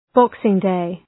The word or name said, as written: Boxing Day